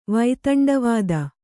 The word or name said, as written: ವೈತಂಡವಾದ